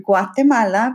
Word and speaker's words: Guatemala: Guatemala, because Rusk corrected me the other day. Don't sound like you don't know how to say it. Say it correctly.